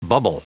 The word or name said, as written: bubble